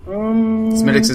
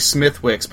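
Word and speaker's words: Smithwick's: It looks like Smithwick, but you don't pronounce it that way.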